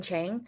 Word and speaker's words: Zhang: Um, hi, I'm Li Ting Chang. And um, for Chinese pronunciation, uh, my uh, name is Zhang Li Ting.